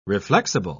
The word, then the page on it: reflexible: British English